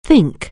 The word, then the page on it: think: anglais